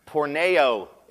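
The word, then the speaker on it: porneia: Sexual immorality is pronounced porneo in Greek. Yes, it's where we get the word porno from.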